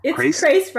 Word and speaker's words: Crace: Crace rhymes with case.